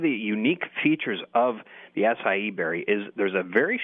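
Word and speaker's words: açaí: I spell it A-C-A-I berry. How do I pronounce that? – It's pronounced the acai berry, believe it or not.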